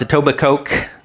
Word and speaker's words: Etobicoke: It's pronounced Etobicoke.